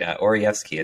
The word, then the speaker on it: Oraevsky: Oraevsky, it's a bit of a difficult tongue twister.